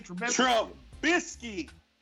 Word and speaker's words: Trubisky: Trubisky. It's an emphasis on the B.